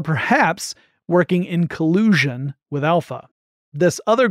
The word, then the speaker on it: ALPHV: also known as Alpha, except it's spelled A-L-P-H-V, that this group was behind the attack.